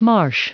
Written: marsh

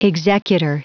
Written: executor

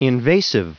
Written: invasive

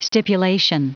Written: stipulation

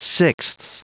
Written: sixths

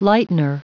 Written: lightener

